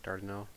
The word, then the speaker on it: Dardanelle: Am I saying that right? Dardanelle, yeah.